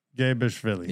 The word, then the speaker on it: Mgebrishvili: Mgebrishvili.